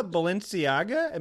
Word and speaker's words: Balenciaga: I saw Balenciaga. Am I saying that right? – You're saying that right.